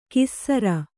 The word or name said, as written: ಕೀಸರ